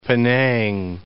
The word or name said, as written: Penang